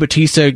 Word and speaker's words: Bautista: Am I saying that right? Bautista? – Yeah. Dave Bautista. – Okay. So he is offering a $20,000 bounty for maggots. That's M-A-G-A-T-S, who defaced a manatee.